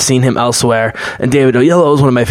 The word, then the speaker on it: Oyelowo: One of my favorite modern actors, who I'm finally able to pronounce his name after a couple of years, David Oyelowo,